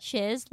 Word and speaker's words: Shiz: Shiz, like you would think. S-H-I-Z.